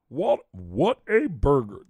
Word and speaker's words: Whataburger: What a burger.